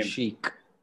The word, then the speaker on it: Schick: Patrick Schick or Sheik, however you say his name. – Sheik.